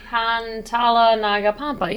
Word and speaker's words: Pantala Naga Pampa: The album starts off with, like, It's called Pantala Naga Pampa, I believe. Or yeah, you can probably help me out with the pronunciation of it. – Pantala Naga Pampa, yeah.